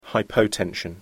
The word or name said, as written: hypotension